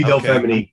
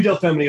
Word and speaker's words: Del Femini: Del Femini. Is that how you say it? – Absolutely. It's O.V. Del Femini. – I'm glad I got it right. – You got it right. It's O.V. Del Femini, affectionately known as Del.